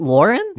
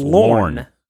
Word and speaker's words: Lorne: Lorne Michaels. Am I saying it wrong? – Yes. Lorne. Yes. Yes. Yeah. You're still saying Lauren. – You are saying Lauren. – Isn't it Lauren? – No, it's Lorne. – Oh, it's Lorne.